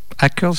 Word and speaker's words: Hackers: Hackers, c'est comme ça qu'on prononce mm-hmm.